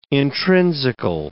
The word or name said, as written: intrinsical